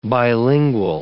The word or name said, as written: bilingual